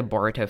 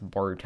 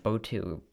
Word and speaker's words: Boruto: You know, I used to not even know how to pronounce Boruto. I said like Botu, Boto, Boto, something like that.